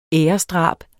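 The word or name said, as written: æresdrab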